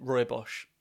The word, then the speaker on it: Rooibos: And there was someone sort of moderately early on who very, very confidently pronounced it Rooibos. And I've gone with that ever since. – Sure. Redbush, that. – Yeah. – That's what I usually just default to Redbush because it saves the problem. Rooibos.